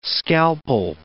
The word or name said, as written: scalpel